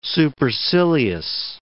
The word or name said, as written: supercilious